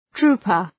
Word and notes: trouper: ‘tru:pər

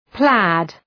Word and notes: plaid: plæd

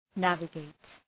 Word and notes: navigate: ‘nævə,geıt

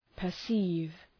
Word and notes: perceive: pər’si:v

